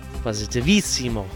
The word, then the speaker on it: Positivissimo: This track, Rebolledo Positivissimo. Did I say that right? – Positivissimo.